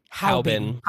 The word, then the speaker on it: Haobin: It just says, How do you pronounce H-A-O-B-I-N? – Haubin.